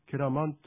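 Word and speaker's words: keraman: このサルガ流域ではこれが縮まってですね、ケラマンというふうに発音します。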